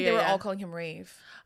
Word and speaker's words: Ralph: So this is from someone named Alexandria, and I just learned how to say this guy's name. It's Rafe Fiennes.